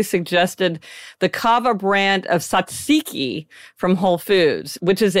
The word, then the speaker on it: tzatziki: Yeah, tzatziki.